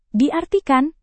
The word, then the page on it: diartikan: Indonesian